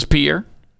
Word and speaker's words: Pierre: A lot of people call it Pierre, and it's not. That's not how you pronounce Pierre, South Dakota. – What? – What? Did you think it was Pierre? – It's not. – No, it's Pierre. It's not Pierre.